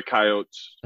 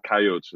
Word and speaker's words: Coyotes: because you know we're the Coyotes. Emphasis on Coyotes. It's not Coyote. That's sacrilegious to say that. That's not the correct pronunciation. So it's Coyotes, not Coyote.